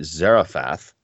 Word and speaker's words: Zarephath: Zarephath.